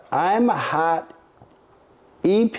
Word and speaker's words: Imhotep: But we have gauges and thermometers, and I'm looking at the I'm Hot EP. How do you pronounce that? I'm H-O-T-E-P. – Oh, Imhotep. Imhotep Steam Lab.